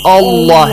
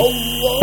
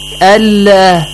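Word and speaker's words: Allah (الله): Whenever Fatha or Dhamma comes before the word Allah, you have to pronounce it Allah heavily. – Allah. – But if Kasra comes before it, Allah.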